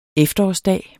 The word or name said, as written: efterårsdag